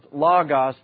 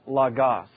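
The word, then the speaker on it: logos (λόγος): In other words, this word is pronounced logos, not logos.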